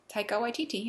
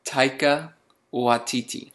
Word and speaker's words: Taika Waititi: Taika Waititi, yeah. – Taiki, ta, ta, yeah. – Ta, try again. Ta, Taika Waititi.